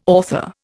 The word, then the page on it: author: Indian